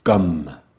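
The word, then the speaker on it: Gam: there's a seed mantra that is pronounced gum, as in chewing gum, gum.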